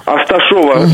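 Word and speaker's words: Асташёва: Асташова или Асташева? – Асташова, через Йо. – Асташова. – Да, это такое вот ударение.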